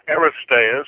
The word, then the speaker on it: Aristeas: We can now continue with a more detailed description of the temple and Jerusalem as provided by Aristeas. Is that pronounced correctly? – Aristeas.